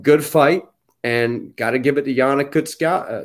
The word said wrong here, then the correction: Kunitskaya: uh, Kunitskaya.